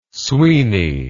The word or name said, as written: sweeny